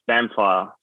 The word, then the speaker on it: samphire: How do you say samphire fish? What's that one? – Samphire. So I mentioned briefly before samphire.